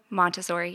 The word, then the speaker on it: Montessori: So the Grow at Eden is Montessori. Is that how you say it? Montessori? – Montessori. Yeah.